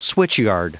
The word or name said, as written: switchyard